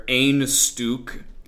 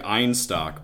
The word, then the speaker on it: Einstök: And speaking of cold things, I'd like to tell you a little bit about where Einstuk is. That is how it's pronounced, by the way. It looks like Einstock, but Icelandic is a tough language.